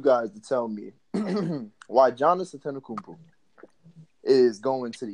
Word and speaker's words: Giannis: I mean, the man tell you on the Metro PCS commercial is pronounced Giannis.